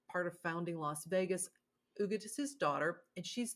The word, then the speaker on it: Huguette: Empty Mansions, The Mysterious Life of Ugud Clark and the Spending of a Great American Fortune by Bill Dedman. So Ugud Clark, which is spelled H-U-G-U-E-T-T-E, and I'm like, how do you even pronounce that name?